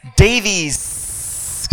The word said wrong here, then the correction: Davis: because there's many S's in. Davis County Library for having me.